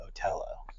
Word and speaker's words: Otello: And then one year we did a production of Othello, and everyone, or as they say in opera, Otello, because they've got to be like that. – Well, it's in Italian, and that's how they pronounce it.